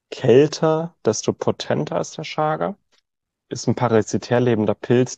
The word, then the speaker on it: Chaga: Chaga, auch einfach Chaga ausgesprochen, auch wenn es CH, Chaga, das kommt aus dem Sibirischen.